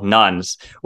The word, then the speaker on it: nones: So there's this term that you constantly use throughout the series called nuns, where it's spelled N-O-N-E-S, but it's, like, pronounced the same way as, like, Catholic nuns, which is a nice pun.